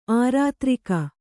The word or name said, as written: ಆರ್ದರಕ